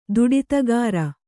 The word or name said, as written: ದುಡಿತಗಾರ